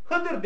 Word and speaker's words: Hızır: Yani Hızır değil de Hıdır dese doğru. Yanlış değil. Ama Türkçe'de dediğimiz gibi Türkiye'de telaffuz genelde dat harflerinin ne okunması yönünde? Z okunması yönünde. Her ikisi de caizdir diyor İbn Hacer.